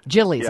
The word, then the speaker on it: Jilly's: It looks like she talks about, and I'm going to pronounce this wrong because I'm not, Gillies? Is that a custard stand? – Jilly's. – Yeah. I'm sorry, Jilly's,